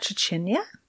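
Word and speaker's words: trzcina: Oh, would that be, I can see it in the list, is that Chechnya? – That's definitely how you say it.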